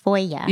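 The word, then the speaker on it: Fueguia: Oh, I say Foygia, but yes. How do you say it? – I asked them to clarify for me because I was like, Fujawa, like it's Foya. – Yeah. – It's easier Foya.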